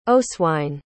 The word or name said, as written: oswine